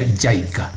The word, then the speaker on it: JICA: por sus siglas en inglés, que se pronuncia JICA.